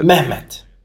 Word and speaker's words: Mehmet: So then it was, there was Istanbul Agop and then there was Istanbul, how do you say it? – Mehmet. – Oh, Mehmet.